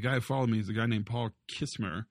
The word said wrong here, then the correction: Chrismer: Chrismer. I said it wrong.